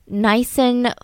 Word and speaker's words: Nissen: The doctors underwent a, I'm not going to say this right, I want to say Nissan, it's not a Nissan, a Nissen funduplication procedure.